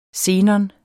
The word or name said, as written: xenon